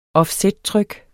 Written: offsettryk

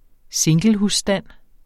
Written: singlehusstand

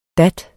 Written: dat

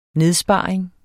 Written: nedsparing

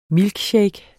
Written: milkshake

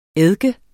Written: eddike

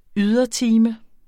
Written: ydertime

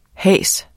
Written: hase

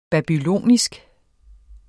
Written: babylonisk

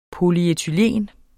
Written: polyethen

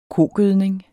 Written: kogødning

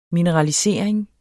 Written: mineralisering

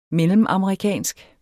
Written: mellemamerikansk

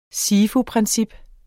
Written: SIFU-princip

